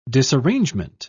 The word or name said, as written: disarrangement